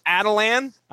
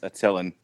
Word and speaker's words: Attilan: And I'm going to pronounce this as Atalan. I don't know if you guys are going. – No, it's Attilan.